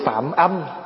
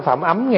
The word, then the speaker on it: Phạm âm: Phạm âm chứ không phải phạm ấm nha. Đó, thí dụ chữ vậy. Mà nếu mà người không biết thì cứ đọc là phạm ấm, còn mình biết thì mình đọc là phạm âm.